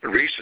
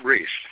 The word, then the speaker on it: Ries: Reese is spelled R-I-E-S. And when you go to vote, I'll be the smallest name on the ballot, Bob Reese, with the biggest heart, of course, okay?